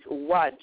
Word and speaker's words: wadj: The Kemetic word for green is waj. You'll find it in various dictionaries as U-A-D-J or U-A-T-C-H, but the U is pronounced as a W-U-U-W-A-J. Also, you will see it spelled W-A-D-J for those who spell it out phonetically,